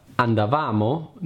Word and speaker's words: andavamo: Andavamo non si pronuncia andavamo.